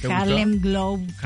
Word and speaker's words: Harlem Globetrotters: porque estaremos con los Harlem Globetrotters. O sea, ¿lo dije bien? – Harlem Globetrotters.